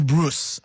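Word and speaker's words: Broos: How do you pronounce it? Bruce. – Yeah. – Somewhere there. Yeah. No, man. Hugo Bruce, I think, is the term, is the right pronunciation.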